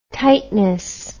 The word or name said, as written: tightness